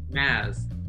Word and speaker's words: Mads: Mads Mikkelsen. Pronounced Maz.